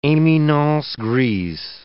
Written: eminence grise

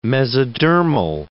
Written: mesodermal

